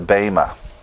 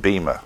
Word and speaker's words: bema: We call that the Bema. Some pronounce it Bema. Tomato, tomato. Bema, Bema. I was taught in Greek to pronounce it Bema. But that's okay if you want to say Bema.